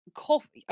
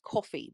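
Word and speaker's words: Ko-fi: But yeah, so the address is, it's coffee, apparently it's pronounced coffee, but it's spelt KO hyphen FI coffee.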